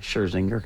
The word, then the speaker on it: Scherzinger: Is that how you say her name? – Yeah, Scherzinger.